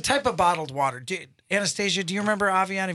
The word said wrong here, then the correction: Evian: You mean Evian? – Evian.